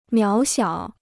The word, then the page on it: 渺小: miǎo xiǎo